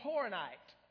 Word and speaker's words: Horonite: He was what was called a Horonite. Okay, that's kind of a scary word, but it's spelled H-O-R-O-N-I-T-E.